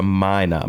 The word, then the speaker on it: mina: It's a long I sound, so it's a mina.